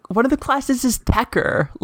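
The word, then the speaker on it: Techer: and he pronounces it Tetcher, and I hate that. I despise that.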